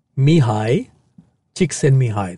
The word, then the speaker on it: Csikszentmihalyi: Mihai Csikszentmihalyi. – Okay, so this is a book called Flow. – Okay. – Written by Mihai Csikszentmihalyi.